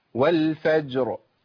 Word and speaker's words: والفجر: زي كمان والفجر، برضو جيم ساكنه وبعديها راء، فجر فجر، يبقى ناخد بالنا، يبقى انا محتاج ان انا اظهر الساكنين، ما اقولش والفجر ولا والفجر والفجر، ما ينفعش، عشان احرك لازم اسكن الراء واسكن الجيم اللي قبلها والصاد اللي قبلها في ايه؟